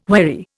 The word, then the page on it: wherry: Indian